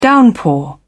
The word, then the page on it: downpour: British English